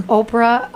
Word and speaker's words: Oprah: What opera? Do you remember? – Oprah. I'm sorry, Winfrey? – I said it wrong. – Oprah Winfrey, huh? – This is my accent. Oprah Winfrey.